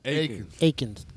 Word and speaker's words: Akins: Yo, we'd like to give a shout out to Jamie Atkins. – Akins. – God, I still can't pronounce Akins. – Big Bluto. – Akins. Jamie Akins.